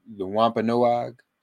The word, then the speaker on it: Wampanoag: The Wampanoag, am I pronouncing that correctly? Is that accurate? – That's correct.